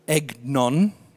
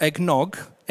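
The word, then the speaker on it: egnon (ἔγνων): But it's this Greek word, egnon, not eggnog, egnon.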